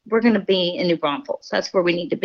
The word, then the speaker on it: New Braunfels: Oh, New Braunfels. – New Braunfels. Okay. Yes. – Yeah, everybody wants to say New Braunfels, but yeah, it's New Braunfels.